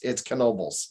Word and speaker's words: Knoebels: They don't pronounce the K like that. Oh, I know. Okay, right. – So we did the K just to emphasize we do. It's Knoebels.